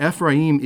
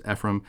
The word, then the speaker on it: Ephraim: But Ephraim is important, or Ephraim. People pronounce it as Ephraim.